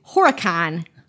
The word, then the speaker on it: Horicon: Oh, and you say it like you're not from Wisconsin. Yeah. if you're from Wisconsin, you say HorrorCon.